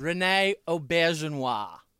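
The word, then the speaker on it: René Auberjonois: Rene Aubergenois. – Ooh, closer than I thought you guys would get. – Aubergenois. – No. – Aubergenois. – Rene Aubergenois.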